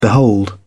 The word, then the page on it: behold: British English